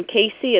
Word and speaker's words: Cassie: And after you see it spelled, nobody ever pronounces it right either. Yeah, I'm sure they say Cassie.